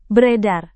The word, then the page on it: beredar: Indonesian